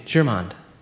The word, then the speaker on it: Germond: Soft G. Soft G. Germond.